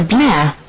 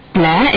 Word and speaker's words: Blair: Jessica Blair. Blair is spelled as B-L-A-I-R.